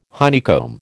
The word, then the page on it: honeycomb: American English